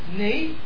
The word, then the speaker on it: knee: Knee. It's not a good try. It's got a really silent K at the front, so it's got four letters.